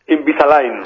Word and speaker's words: Invisalign: Y sobre todo de, a ver si lo digo bien, Invisalign. – Invisalign. – Ah, vale, Invisalign. Vale, Invisalign. Pues... Hoy vamos a hablar de Invisalign. – Bueno, pues vamos a hablar qué es Invisalign. – Bueno, Invisalign